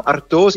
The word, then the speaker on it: Artois: Die wa achteraan, dat is natuurlijk de Franse uitspraak. En de naam Artois is in ieder geval een verbastering van een Frans woord.